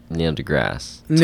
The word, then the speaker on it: Neil deGrasse Tyson: Neil deGrasse Tyson.